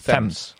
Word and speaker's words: Thames: Ja, want het is namelijk niet de Thames, het is de Thames.